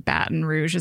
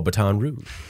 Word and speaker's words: Baton Rouge: No, I just, I was going to say baton, but like, I mean, that's not because I didn't know how to say it. It's because my eyes see that word and I'm just like, oh, Baton Rouge, but it's Baton.